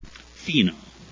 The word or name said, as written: fino